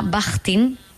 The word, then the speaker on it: Bakhtin: Y lo peor de todo, porque hay un autor ruso que a mí me gusta mucho, que se llama Bajtin, No sé si lo he pronunciado Bakhtin. Bien, pero bueno, ahora mismo no, Bakhtin se escribe.